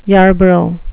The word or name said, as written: yarborough